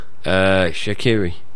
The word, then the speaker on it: Shaqiri: Shaqiri.